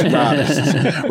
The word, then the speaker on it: Rartists: Okay, so if you hear us rattling off that name, we're not mispronouncing, okay? It's Rartists.